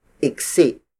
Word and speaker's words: iksi: Bangko, the Tagalog word for bench, is spelled as B-A-N-G-K-O. Ikli has two other variations, namely igsi. Igsi. Igsi, which is spelled as I-G-S-I. And iksi. Iksi. Iksi, which is spelled as I-K-S-I.